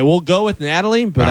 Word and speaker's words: Nathalie: According to court documents, the Miami-Dade County, How would you say it? N-A-T-H-A-L-I-E. – Natalie. – That's Natalie? – Yep. – With an H in there? – Yep, it's silent.